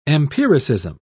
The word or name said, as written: empiricism